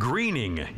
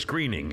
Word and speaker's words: Groening: Cartoonist Matt Groening. It's actually spelled G-R-O-E, but it's Greening. – Groening. – I believe it's pronounced G-R-E-E-N, Greening.